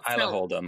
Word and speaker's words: Isla Holdham: You've put Ayla Holdham, am I saying that correctly? – And Isla. – Isla. – Yeah, spelled Isla Holdham. – Isla Holdham. Yeah,